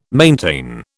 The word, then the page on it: maintain: British English